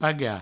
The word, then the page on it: pagar: paga